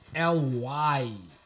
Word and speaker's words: Lynx: Lynx. Lynx is spelled LYNX. – Yeah, man. – That is Lynx, right? – That's Lynx, man.